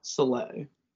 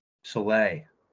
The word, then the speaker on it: Soleil: How do you say her last name? – Soleil. – Soleil. – Yeah, it's sun in French.